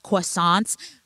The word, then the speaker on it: croissant: Orange cranberry biscuits, chocolate avalanche croissants. – Croissant. – Get out of town. – You said croissant. You said it right.